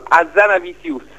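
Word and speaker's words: Hazanavicius: Allora, l'ho cercato su Google, c'è un programmino che ti dice che ti dice come si pronunciano, Azanavicius. Michel Azanavicius.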